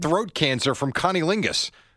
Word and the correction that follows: cunnilingus: Isn't that how you pronounce it? – He did say that. – And Catherine Zeta-Jones nearly threw him out the house. – I think it's Cunnilingus.